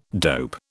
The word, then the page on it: dope: British English